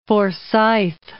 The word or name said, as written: Forsyth